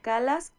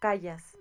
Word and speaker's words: Callas: ¿Cómo se pronuncia en español? ¿Calas o Callas? – María Calas. – Ok, es que yo también le decía Callas.